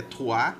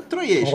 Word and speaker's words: Troyes: É um nome francês que é Troyes. Mas é troiês, cara.